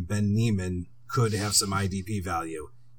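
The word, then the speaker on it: Niemann: So Ben Neiman is, you know, I even want to say it like how Seinfeld says Newman. Neiman. – Yeah. Neiman. Hello, so, Neiman.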